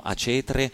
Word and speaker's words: Acetre: E c'è un gruppo che va avanti ormai da tantissimi anni, anche questo pubblica da poco il suo nuovo album, Acetre, si chiamano Acetre, pronuncia sì, sicuramente è questa,